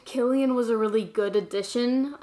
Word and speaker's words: Cillian: For the longest time, I thought his name was pronounced Cillian Murphy. Isn't that embarrassing? – That's embarrassing. – Yeah. And he's our cousin, and I was saying it wrong.